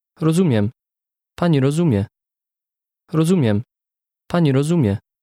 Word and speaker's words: Rozumiem: Rozumiem. Miem. Miem. Zumiem. Zumiem. Ro. Rozumiem. – Did you hear the M sound at the end?